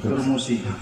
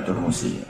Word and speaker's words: Turmusi: Kalau sini bacanya Tarmasi atau Turmusi umumnya? – Tarmasi. – Turmusi. Ya Turmusi.